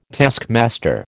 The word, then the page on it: taskmaster: American English